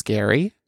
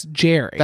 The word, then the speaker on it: Gerry: His name is spelled Jerry, G-E-R-R-Y, but apparently it's Gary. I don't like that. I don't like that. – G-E-R-R-Y? – Yeah. – That's Jerry. – That's Jerry. But he says Gary.